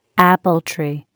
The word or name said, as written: apple tree